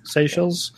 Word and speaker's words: Seychelles: Seychelles.